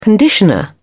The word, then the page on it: conditioner: British English